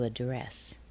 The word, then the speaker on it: address: But when we use the verb, we say to accent. To accent. An accent. To accent. And the next one? An address. But to address. To address. So notice how weak the first syllable is.